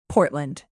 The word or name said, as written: portland